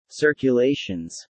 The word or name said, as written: circulations